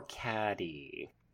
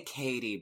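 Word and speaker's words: Cady: Is it Katie or Caddy? – Oh my god. – They do say Katie, but it's spelled Caddy. – Yeah. – With one D.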